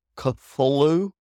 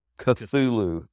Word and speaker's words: Cthulhu: Yeah, before we get too far into this, Bob, though, let's just for the listeners, the pronunciation, Cthulhu, is that correct? – No, Cthulhu. – Cthulhu. There we go. Cthulhu. Sorry, I always say it wrong, even though I know what the creature is. I've read the stuff. I just can never say it right. It's always messing me up. – It's a harder C and then a softer T and H, almost as if your lips and tongue are maybe not altogether solid.